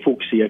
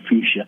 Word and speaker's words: fuchsia: I say fuchsia because that's how you're supposed to pronounce it, not fuchsia.